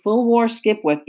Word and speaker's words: Fulwar Skipwith: Now, you know I like Saint-Denis' name, but I got to say, Fulwar Skipwith is my absolutely favorite name from Louisiana history, and his name is spelled exactly the way it sounds, Fulwar Skipwith.